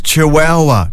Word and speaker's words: Chihuahua: A member for the Kimberley said that she would fight like a Chichuana to retain regional representation. – It's pronounced Chihuahua. Chihuahua. So what we'll do, we'll give him a buzzer, a wrong buzzer, every time he says Chihuahua wrong. Here we go.